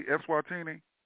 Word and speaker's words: Eswatini: Am I pronouncing that correctly, Eswatini? – Yes, you're correct. – Okay. And before it was, Swaziland or Swaziland? – Swaziland.